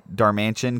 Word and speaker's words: Darmanitan: I think I might use Galarian Darmanitan because Carl just gave me eyes.